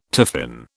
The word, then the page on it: tiffin: British English